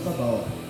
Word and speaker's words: Sabaoth: So that because God, the Lord of Sabaoth, I'm not an Hebrew, so I cannot really pronounce it, but his name is just S-A-B-A-O-T-H, Sabaoth.